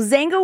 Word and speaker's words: Xanga: It's spelled X-A-N-G-A. So Zanga was before MySpace, before everything.